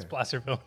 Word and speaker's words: Placerville: Okay, first of all, that reasoning is wrong, but you're right with your pronunciation. Yeah. It's Placerville.